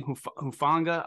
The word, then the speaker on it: Hufanga: And I wish I could pronounce their safety's name, Hufanga.